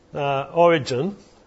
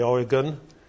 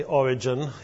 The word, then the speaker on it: Origen: Origen, as he is usually pronounced, some people say Oregon. I usually say Origen.